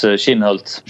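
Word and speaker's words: Kinhult: Matthias Schwab and Marcus, is it Kinholt? Is that how you pronounce his name? – Yeah, Marcus Schinholt.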